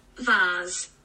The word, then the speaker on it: vase: Vase. – That's the right way to say it. – So, vase. All you Yanks.